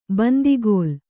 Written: ಬಾಂದುಕಲ್ಲು

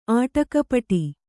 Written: ಆಟಕಪಟಿ

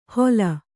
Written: ಹೋಲ್

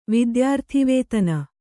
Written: ವಿದ್ಯಾರ್ಥಿವೇತನ

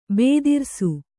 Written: ಭದ್ರಿಸು

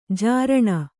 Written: ಝರಣಿ

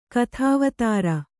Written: ಕಥಾವತಾರ

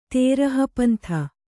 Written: ತೇರಹಪಂಥ

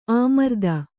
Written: ಅಮರಧೇನು

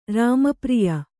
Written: ರಾಮಪ್ರಿಯ

